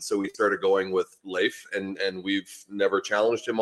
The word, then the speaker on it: Leif: And so it is Leif, L-A-Y-F, in terms of actual pronunciation. So it is Leif.